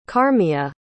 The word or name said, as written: carmya